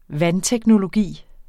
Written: vandteknologi

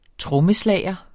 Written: trommeslager

